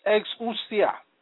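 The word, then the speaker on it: exousia (ἐξουσία): The Greek word for power in this text of scripture is pronounced exousia, meaning force, captivity, competency, freedom, mastery, delegated influence, authority, jurisdiction, liberty, power, right, and strength.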